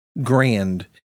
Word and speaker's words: Grande: Grand. George G-R-A-N-D-E. – Grand. The Grand Minute. It's a big amount of news in a small amount of time. – 100 grand. 100 hours of George Grand.